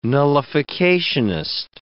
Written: nullificationist

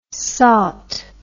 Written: saut

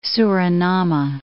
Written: Suriname

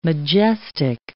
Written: majestic

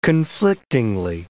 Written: conflictingly

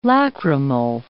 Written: lachrymal

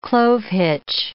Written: clove hitch

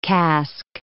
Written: cask

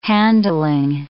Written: handling

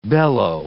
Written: bellow

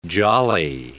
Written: jolly

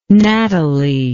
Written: Natalie

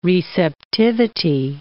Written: receptivity

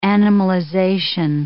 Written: animalization

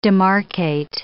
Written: demarcate

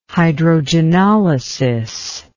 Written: hydrogenolysis

